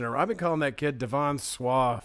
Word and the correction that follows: Sawa: for a long time. – Are you serious? – Absolutely. – I'm pretty sure it's Devon Sawa.